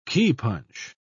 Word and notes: keypunch: British English

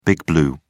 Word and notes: big blue: British English

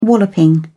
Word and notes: walloping: British English

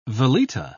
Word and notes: veleta: British English

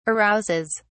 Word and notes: arouses: British English

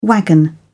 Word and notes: wagon: British English

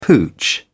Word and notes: pooch: British English

